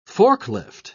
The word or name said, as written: forklift